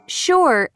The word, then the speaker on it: sure: Sure and sugar are the only two words in the English language that begin with S-U and are pronounced sh.